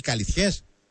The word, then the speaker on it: Καλυθιές: Ο Ραφαήλ κατάγεται από τη Ρόδο και συγκεκριμένα από τις Καλυθιές της Ρόδου. Σωστά το λέω, ναι. Ραφαήλ, Καλυθιές. – Πολύ σωστά.